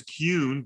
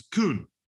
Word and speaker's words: Kuhn: And in American is Kuhn, but in German it's Kuhn.